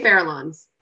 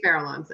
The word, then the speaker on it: Farallones: It's tricky to spell and to pronounce correctly, which we don't really do. – Oh, dear. You better tell me how to pronounce it. – I think it's supposed to be, well, we say Farallones.